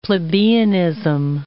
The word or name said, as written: plebeianism